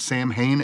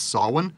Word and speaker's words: Samhain: Which is the correct pronunciation of it's not Samhain, it's Samhain.